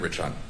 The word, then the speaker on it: Rich Hunt: Lieutenant Rich Hunt. – Right. Can you spell it? – R-I-C-H-H-U-N-T.